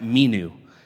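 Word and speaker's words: menu (μένω): And the word abide in Greek here is pronounced menu.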